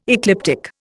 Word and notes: ecliptic: Indian

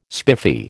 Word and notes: spiffy: American English

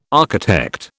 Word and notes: architect: British English

